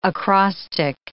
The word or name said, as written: acrostic